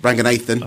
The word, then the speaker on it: Ranganathan: Also at Waterstones in Birmingham on Saturday 15th December, Romesh Ranganathan. – Ranganathan, I think. – Sorry, I was one syllable out.